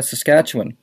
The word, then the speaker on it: Saskatchewan: Can I pronounce that properly? – You said it right. Not Saskatchewan, people. Take it from me. I know somebody who was born there, Glenda, and you do not say Saskatchewan any more than you say Newfoundland.